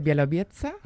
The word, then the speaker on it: Białowieża: Parque Nacional de Białowieża.